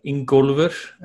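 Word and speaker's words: Ingólfur: But my full name is Ingolfur, or Ingolfur as we pronounce it back home. Ingolfur Gudmundsson would be the Icelandic pronunciation, but nobody could say it like that.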